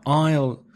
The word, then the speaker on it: aisle: Now, aisle, A-I-S-L-E, that's a silent S, is pasillo.